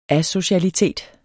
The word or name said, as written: asocialitet